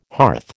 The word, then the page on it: hearth: American English